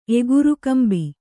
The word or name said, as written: ಎಗುರುಕಂಬಿ